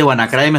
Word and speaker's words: WannaCry: Ese WannaCry me sonó a Guanaco. – Pronuncié bien, WannaCry. – No, está bien.